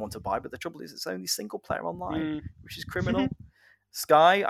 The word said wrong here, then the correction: Scythe: I don't know if I'm pronouncing that right. Scythe? – I think it's Scythe.